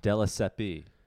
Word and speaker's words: Della Siepe: Della Seppi, or is it just Della Sepp? – Della Seppi.